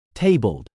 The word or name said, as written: tabled